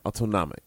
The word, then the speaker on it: autonomic: Experienced by males and females, orgasms are controlled by the involuntary or autonomic. What's the correct pronunciation? – Autonomic. – Autonomic.